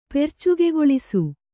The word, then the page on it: ಪೆರ್ಚುಗೆಗೊಳಿಸು: percugegoḷisu